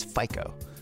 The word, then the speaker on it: FICO: I checked out the pronunciation, that's FICO.